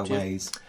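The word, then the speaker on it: Weyes: No idea how you pronounce it. – I go Ways.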